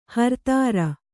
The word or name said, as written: ಹರ್ತಾರ